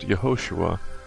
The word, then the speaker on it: Yehoshua: The fifth chapter of the book of Joshua, or more accurately pronounced Yehoshua, contains what I consider to be two important events that have a single lesson for us.